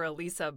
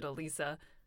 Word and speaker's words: Elissa: I'll respond to Alyssa or Alisa, but I personally pronounce it Alisa. – I am so sorry. I honestly thought it was Alyssa.